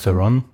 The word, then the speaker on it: Theron: Theron. Wie spricht man das richtig aus? – Ja, passt. – Theron.